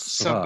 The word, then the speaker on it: Savage: Which, spelled Savage. – Right. But pronounced Savage. Savage.